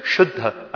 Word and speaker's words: शुद्ध: And you say शुद्ध as in Buddha.